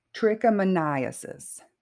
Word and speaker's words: trichomoniasis: Now, the one that is sexually transmitted is it's called I'm going to have to pronounce this correctly trichomoniasis.